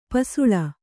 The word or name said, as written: ಪಸಲು